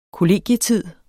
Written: kollegietid

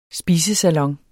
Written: spisesalon

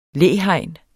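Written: LÆHEGNET

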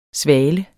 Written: svale

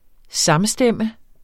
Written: samstemme

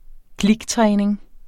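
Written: kliktræning

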